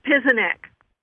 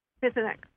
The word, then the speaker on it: Pieczenik: Pizinskit. P-I-E-C-Z-E-N-I-K. Is this information accurate? – It's Pizinek. Pizinek. – Oh, Pizinek. Okay.